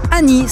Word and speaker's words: AN2I: Euh, on le doit à quelqu'un qui s'appelle Annie, ça s'écrit A-N-2-I.